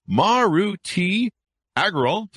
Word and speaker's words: Maruti Agarwal: It's Maruti Agarwal.